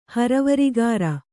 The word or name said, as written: ಹರವರಿಗಾರ